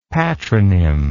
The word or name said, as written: patronym